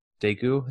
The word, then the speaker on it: Daegu: I didn't like my least favorite one was actually Meet Me in Daegu. Is that how you say it? – Oh yeah.